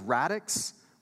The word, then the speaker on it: radix: This word radical, the Latin for this word radical is radix, which sounds like the word radish.